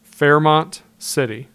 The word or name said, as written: Fairmont City